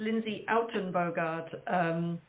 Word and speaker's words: Lindsay Uittenbogaard: Very close. It's Lindsay Uittenbogaard. Yeah. But you're forgiven. It's a ridiculously long, difficult to pronounce their name.